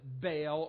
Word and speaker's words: Baal: His name is spelled B-A-A-L. We sometimes pronounce it Baal or sometimes Baal, as maybe how you might pronounce it.